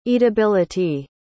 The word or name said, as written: eatability